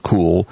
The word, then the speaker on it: Cuil: Again, s- pronounced Cool, spelled C-U-I-L com, That's one of the many search engines around.